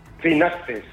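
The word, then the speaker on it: Finaccess: Eh, oye, ¿cómo lo, lo, pronunciáis? Eh, ¿Finaces con acento de la e S o Finaces con acento de la A? – Finaces. – Finaces. Eh, eso es.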